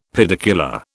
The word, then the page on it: pedicular: British English